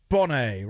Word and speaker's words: Bonet: Lisa Lisa Bonet, right? Silent T on the end. Bonnet, but you don't pronounce the T.